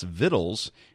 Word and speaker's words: victuals: He says, "Victuals is one of those British words that is not pronounced the way it is spelled, like Worcester is pronounced Wooster. Victuals is pronounced Vittles and is commonly used to stereotype folks from Appalachia and the Ozarks, like Granny from the Beverly Hillbillies."